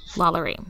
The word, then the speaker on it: LaLaurie: How do you say her last name? I've heard it differently. – LaLaurie. – Delphine LaLaurie. – So, yeah, or that's how it's pronounced on... American Horror Story, Coven.